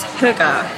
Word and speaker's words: hygge: We're not entirely sure how to pronounce it, but we have it on good authority that it's hygge. – Is that H-U-E-G-A-H? Phonetically? – Phonetically, yes. – Hygge. Okay, but it's actually spelled H-Y-G-G-E.